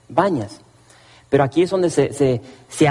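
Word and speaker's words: Banias: Pero los árabes no pueden pronunciar la P. Entonces ellos pronuncian sus P's como B's. Y le dicen Bañas.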